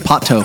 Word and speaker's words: Pato: Pato. – How do you spell it? – P-A-T-O. – Pato Banton. – Yeah. – Not potato.